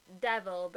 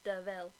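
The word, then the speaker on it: De Vil: He's like, it's spelt Devil, but it's pronounced Deville, and I was like, I didn't even notice that.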